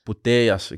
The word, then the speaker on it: Putellas: Is Alexia Putellas? – Putellas, ik moet de L niet uitspreken.